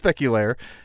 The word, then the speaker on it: Peculiar: And I would say check out our podcast that we did together a few years ago called Siblings Peculaire, pronounced peculiar. I'm sorry, spelled peculiar, pronounced peculaire.